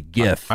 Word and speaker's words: GIF: 68% agree that it's with the hard G. GIF.